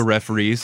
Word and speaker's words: referees: But there's an apostrophe, so it's only two syllables. – Fuck the referees.